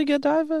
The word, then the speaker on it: Godiva: Is it really Godiva? – Yeah, it's Godiva. Lady Godiva.